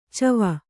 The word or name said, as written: ಚವ